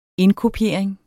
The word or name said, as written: indkopiering